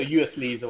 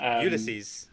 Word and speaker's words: Ulysses: So useless or Ulysses or whatever you pronounce it. – Ulysses. – Oh, Ulysses. Ulysses.